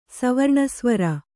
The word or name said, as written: ಸವರ್ಣಸ್ವರ